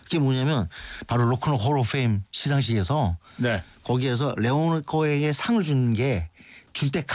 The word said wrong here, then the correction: 레너드 코헨: 야 레너드 코헨 얘기하시는 거죠? – 네 레너드 코헨 발음 이상하게 써가지고 다른 사람인 줄 알았어요.